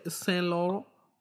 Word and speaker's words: Saint Laurent: Saint Laurent. That's how they say it in the French land.